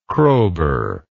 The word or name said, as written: Kroeber